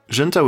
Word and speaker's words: Junta: Junta was another point in Fish's maturation, establishing their sound going forward. In Spanish, Junta, pronounced junta, means meeting, a convergence of sorts.